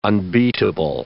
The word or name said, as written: unbeatable